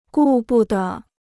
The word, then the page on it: 顾不得: gù bu de